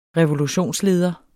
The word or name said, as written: revolutionsleder